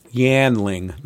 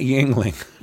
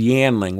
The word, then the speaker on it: Yanling: Yanling, not Yingling. And it's Yanling, Y-A-N-L-I-N-G.